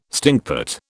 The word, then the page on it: stinkpot: British English